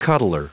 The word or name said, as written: cuddler